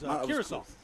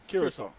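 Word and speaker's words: Curaçao: It was Curacao. – Curacao. – Yeah, Curacao. – There you go.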